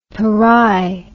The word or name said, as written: Perai